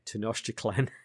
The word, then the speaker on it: Tenochtitlan: They're the exact location of the main temple of, I keep having to look how to pronounce these, Tenochtitlan, is not yet known.